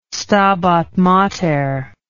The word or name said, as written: Stabat Mater